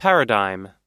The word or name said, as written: paradigm